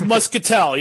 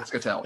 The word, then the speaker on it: Muscatel: And it's aged, I believe, in Muscatel. You'll have to correct my pronunciation, guy, if I butchered that one. – Pretty close. Cool. I think it's Muscatel. Yeah, Muscatel.